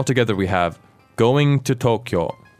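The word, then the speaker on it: 東京: Is it three syllables or two syllables? – Tokyo. So two syllables. – Mm-hmm. And both are long vowels.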